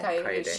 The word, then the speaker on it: Kaede: lady, how do you pronounce Sue? it? Sue. Sue. No, yeah. not Sue, the first one. Oh, uh, Kaede? Kaede.